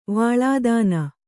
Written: ವಾಳಾದಾನ

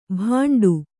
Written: ಬಂಡು

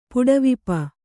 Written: ಪುಡವಿಪ

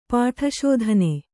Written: ಪಾಠಶೋಧನೆ

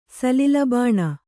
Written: ಸಲಿಲಬಾಣ

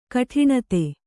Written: ಗಾಡಿನತ್ತು

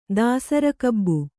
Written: ದಾಸರಿಕಬ್ಬು

